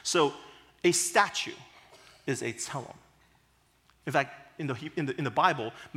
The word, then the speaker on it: tselem: The Hebrew word there is tselem, kind of a T-S, tsa sound, E-L-E-M, with the accent on the first syllable. So if you want to repeat after me, okay, tselem. Good, good, okay.